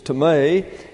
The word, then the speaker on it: timē: That's T-I-M-E, timē. Looks like the English word time, but it isn't. It's timē, and it means honor.